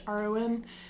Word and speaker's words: Mehron: It's a company called Mehron, I believe is how you pronounce it. It's Mehron. And